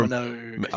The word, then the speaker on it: Minogue: Is that how you say her name? Minogue? Or Minogue. – I was going to say Monique, but then I would have gotten.